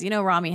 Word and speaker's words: Rami: I want to see Rami. Is that how you say it? I've been saying Rami. Rami Malek? – Rami.